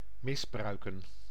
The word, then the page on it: vergewaltigen: /fɛʁɡəˈvaltiɡən/; [fɛɐ̯ɡəˈvaltʰiɡŋ̍]